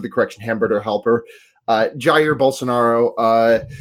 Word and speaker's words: Jair: Uh, speaking of a scary fascism, uh, Yair Bos- Bolsonaro, I believe, is that is that the correct pronunciation? – of Brazil's president? – Yes. Yeah. Jair Bolsonaro. Uh, he is just like, you know, I mean, we like the instant that he was. Oh, Jair. Sorry.